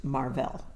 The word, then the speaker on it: Marvell: and his name was the Great Marvell. Mm-hmm. It looks like the word Marvel, but it's pronounced Marvell, the Great Marvell.